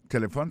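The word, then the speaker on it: telephone: On a remarqué que l'accent tonique là tombé sous premier syllabe là. Si nous besoin de dire bien, c'est téléphone. Alors, ou prononcer ou appuyer plus sous premier syllabe là qui c'est T. Tu me prends encore téléphone.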